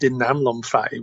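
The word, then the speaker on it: dinamlom phai (ดินน้ำลมไฟ): Or, as they say in Thailand, dinamlom phai, which is earth, water, wind, and fire. That's how they pronounce it here.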